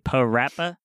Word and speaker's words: Parappa: Parappa the Rapper.